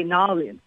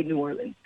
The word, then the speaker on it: New Orleans: You hear it, and the Cajuns, you'll hear the Cajuns say Nolens, but that's really not how we typically pronounce it here. We just say New Orleans.